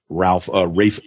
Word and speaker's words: Ralph: I almost said Ralph. Barry Rose, how awesome is this fucking movie? – Well, it is spelled Ralph, so you got to get it. – But it's pronounced Rafe.